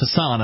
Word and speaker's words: Hassan: At least he is clearly compassionate on this issue, unlike this woman in New Hampshire who was elected last year, Maggie Hassan, I believe that is how that's how they pronounce Hassan up here in New England.